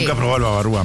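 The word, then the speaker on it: bavarois: ¿Cómo será? – Babarúa. – Babarúa. – Babajúa sería. – Se escribe Babarois. – No, sí, pero sería Babarúa.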